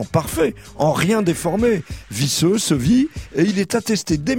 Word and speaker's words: ceuvi: Oui, mais à dire vrai, Yasmina, on trouve développé le sevi orthographié C-E-U-V-I pour qu'on ne prononce pas sévu